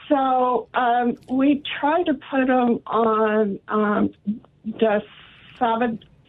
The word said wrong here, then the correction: dasatinib: Is that pronounced wrong? – Dasatinib.